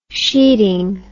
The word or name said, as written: sheading